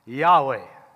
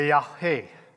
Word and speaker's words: Yahweh: In the scriptures, it's often referred or said, pronounced Yahweh or Yahweh. You got to say it in Hebrew. Hebrew is a guttural language, so you got to put a little throat action into it.